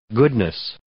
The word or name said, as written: goodness